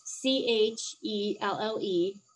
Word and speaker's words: Chelle: It's C H E L L E, Chelle. So like Michelle without the M I.